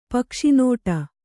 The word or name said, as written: ಪಕ್ಷಿನೋಟ